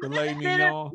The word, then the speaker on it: Filet Mignon: What you call Mignon. It? Filet Mignon. Filet Mignon.